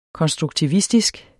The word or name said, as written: konstruktivistisk